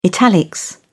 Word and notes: italics: British English

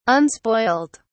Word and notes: unspoilt: British English

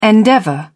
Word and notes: endeavour: British English

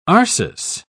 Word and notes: arsis: British English

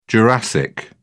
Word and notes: jurassic: British English

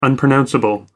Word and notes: unpronounceable: American English

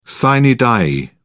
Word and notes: sine die: British English

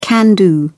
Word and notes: can-do: British English

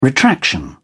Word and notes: retraction: British English

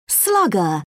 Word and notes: slugger: British English

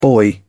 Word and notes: boy: British English

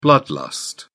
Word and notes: blood lust: British English

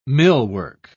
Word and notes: millwork: British English

